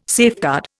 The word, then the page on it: safeguard: Indian